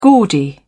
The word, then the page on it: gaudy: British English